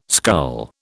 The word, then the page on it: scull: British English